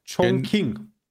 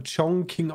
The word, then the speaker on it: Chongqing: Chongqing. – Ja, genau, mit G geschrieben, mit zwei, Äh, ja, das wird so Chongqing ausgesprochen scheinbar, ja.